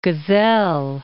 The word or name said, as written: Gesell